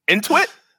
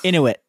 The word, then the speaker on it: Inuit: Intuit? Is that Intuit? – Inuit.